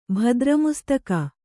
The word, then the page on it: ಭದ್ರಮುಸ್ತಕ: Kannada